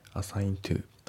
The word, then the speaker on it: assigned: Assigned to Assigned to じゃなくて Assigned to だとずっと右のコンサートの T が抜けますね。